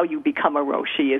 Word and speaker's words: Roshi: And I have to ask, my very first question is, what is a Roshi? And am I saying that right? – You are saying it right.